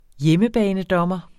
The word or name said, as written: hjemmebanedommer